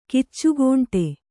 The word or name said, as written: ಕಿಚ್ಚುಗೋಂಟೆ